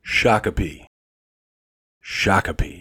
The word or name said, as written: Shakopee